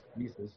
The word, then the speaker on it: Mises: And then I recommended Human Action by Mises, which is actually pronounced Mises, but I like to mispronounce his name.